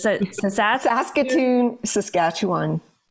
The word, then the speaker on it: Saskatchewan: Is it Saskatoon, Saskatchewan.